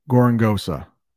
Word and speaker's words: Gorongosa: Talia, I wonder if Elon Musk has to travel to Mozambique or Gorongos. I practiced pronouncing that national park. Gorongosa. Gorongosa is how we say it.